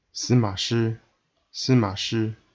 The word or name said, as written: Sima Shi